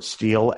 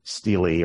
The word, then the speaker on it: stele: They might be written on I always pronounce the word steel, S-T-E-L-E, although apparently it's really pronounced steely or stele or something like that.